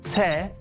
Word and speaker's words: 세: When counting like this, the last letters aren't pronounced. It's han instead of hana, tu instead of tu, se instead of set, ne instead of net, and sumu instead of sumul.